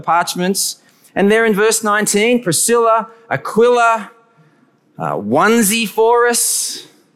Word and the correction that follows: Onesiphorus: Pretty sure that's how you pronounce his name. You did try a onesie. I'm sure it's onesie. No, Onesiphorus, I'm sure, right, Matt?